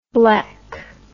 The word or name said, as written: blech